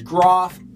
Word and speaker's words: Graf: His name was Groff, after, it's spelled G-R-A-F, it was after these hockey skates, this hockey brand I used to love from Yammer Yager.